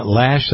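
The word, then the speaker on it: Loesch: Dana Lash, that's how her name is pronounced, I believe.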